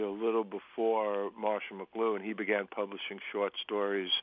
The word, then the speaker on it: McLuhan: McLuhan.